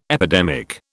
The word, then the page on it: epidemic: British English